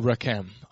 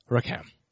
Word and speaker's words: racham: It's a Hebrew word called Rechem. R-A-C-H-A-M. Rechem. If you want to pronounce it right, you probably have to use a lot of phlegm.